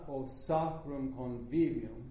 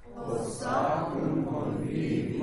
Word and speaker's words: sacrum convivium: Italians don't accent the beginning of a syllable like we do in English. Not o sacrum convivium, but o sacrum convivium. So give it a little bit more of a caress than a punch.